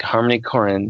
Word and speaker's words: Harmony Korine: And I think Harmony Korine, is that how you say it? – Yep.